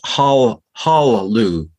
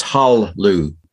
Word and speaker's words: hallelu: Because it's hallelu. There's no le really there in the pronunciation. It's hallelu. Just for any Hebrew files out there, it's a silent schwa, not a vocal schwa.